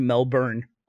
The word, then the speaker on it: Melbourne: Wait, how do you I'm never going back to Melbourne, Australia ever again because you can't say it right. – Just say Melbourne.